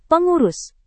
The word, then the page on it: pengurus: indonesian